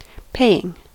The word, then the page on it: paying: American English